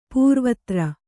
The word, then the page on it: ಪೂರ್ವೋತ್ತರ: pūrvōttara